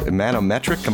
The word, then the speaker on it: Manometric: It's called Manometric. Am I saying that right? – That's correct.